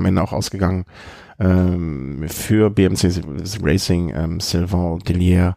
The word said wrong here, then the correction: Dillier: habe ich das richtig ausgesprochen? Dillier? Dillier, ja. Dillier. Genau.